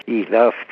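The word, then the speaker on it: إيلاف: نه، عرض کردم که این رو رسانه به اسم ایلاف تلفظ میشه به عربی،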